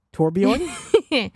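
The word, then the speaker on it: Torbjörn: So the official Play Overwatch Twitter account leaked, in air quotes, leaked archive communications between Ziggler, who's Mercy, and Trollbjorn. I'm not saying that properly. – Torbjorn? – the little guy with the turds.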